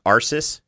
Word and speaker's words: Arsis: Okay, first of all, I think the bear-like creature is, his name is pronounced Arsis, with an emphasis on Ars.